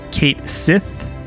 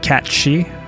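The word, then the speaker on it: Cait Sith: We have Yuffie the Thief, Kate Sith, or as some pronounce, I think Kat Shi.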